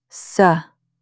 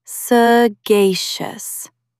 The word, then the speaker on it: sagacious: Stress the second syllable, sagacious.